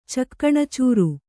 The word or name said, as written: ಚಕಣಾಚೂರು